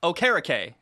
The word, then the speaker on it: Okereke: St- so Bobby Okereke came out and said on Twitter, "It's Okereke is how you say his name."